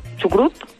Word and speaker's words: chucrut: Bueno, pues entonces tenemos un ofertón que es una jarra de litro, una super sal chicha alemana con puré y chucret por chucrut, no sé cómo se dice. – Chucrut, sí.